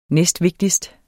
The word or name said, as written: næstvigtigst